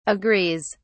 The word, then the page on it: agrees: British English